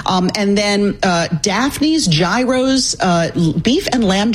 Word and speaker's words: gyros: If you're going to go Greek, it is a euro.